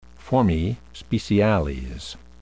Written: formae speciales

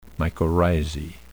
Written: mycorrhizae

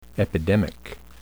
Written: epidemic

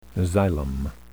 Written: xylem

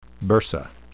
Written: bursa

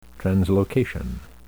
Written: translocation

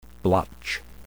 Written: blotch

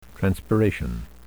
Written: transpiration

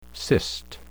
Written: cyst

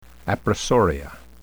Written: appressoria

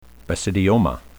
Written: basidioma

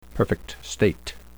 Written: perfect state

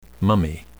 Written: mummy

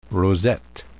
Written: rosette

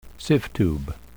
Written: sieve tube

